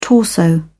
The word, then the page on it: torso: British English